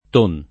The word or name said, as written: Ton